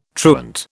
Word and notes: truant: British English

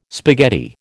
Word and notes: spaghetti: American English

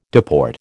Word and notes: deport: American English

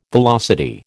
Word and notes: velocity: American English

